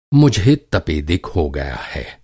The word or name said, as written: तपेदिक